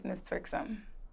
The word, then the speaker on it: Mizz.Twerksum: The correct way to pronounce my stage name is Mizz.Twerksum.